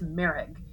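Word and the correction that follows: Merhige: I don't think I'm saying his name right, but I watched the trailer. Apparently, it's Marriage.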